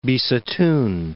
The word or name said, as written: Bisitun